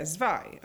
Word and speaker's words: Zwei: um, I think the band is pronounced uh, uh, Zay. Uh, ah, no, I can't say it. It's the German word for two. – Yeah, Zwei.